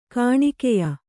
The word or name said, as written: ಗೋನಿಕಾಯ